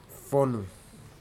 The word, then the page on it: fonu: futuna aniwa